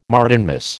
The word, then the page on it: Martinmas: American English